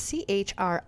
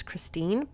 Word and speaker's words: Christiane: It's Christiane. I thought it was Christine, but it's spelled kind of like Christiane.